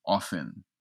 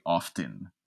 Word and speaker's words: often: He says, "The educated pronunciation is often, but the less adept say often." And he also says that the way we pronounce it with the T is what he calls non-U or not upper class.